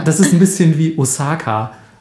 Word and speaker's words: Osaka: Äh, also es heißt, falls ihr jetzt euch gefragt habt, warum, es heißt Hokkaido, dann heißt es Osaka, also ein langes O und Kara ist kurz, ist nicht Kara oder sonst irgendwas, sondern Karate.